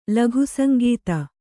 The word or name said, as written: ಲಘುಸಂಗೀತ